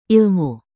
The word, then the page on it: ilmu: indonesian